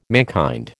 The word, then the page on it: mankind: American English